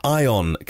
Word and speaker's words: Aion: Their Aion cars, A-I-O-N is how it's spelt. I think I say Aion